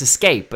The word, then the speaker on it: Xscape: Xscape. I mean, it's Escape, but it's Xscape.